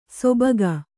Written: ಸೊಬಗ

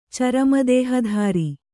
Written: ಚರಮದೇಹಧರ

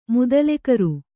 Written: ಮುದಲೆಕರು

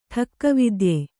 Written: ಠಕ್ಕವಿದ್ಯೆ

